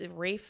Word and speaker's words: Ralph: So this is from someone named Alexandria, and I just learned how to say this guy's name. It's Rafe Fiennes.